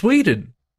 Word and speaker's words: Whedon: You said it wrong. – Is it Whedon? – It's Whedon. – But how can it be? It's there's no long E in there.